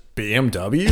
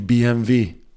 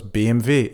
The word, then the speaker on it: BMW: So in Germany, it's actually BMW. – Yeah, because the German W is not pronounced W, it's a V. So it's BMW.